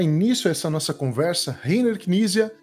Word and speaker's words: Rainer Knizia: Eu falei certo? É Rainer Knizia? – É assim que eu pronuncio. Rainer Knizia também.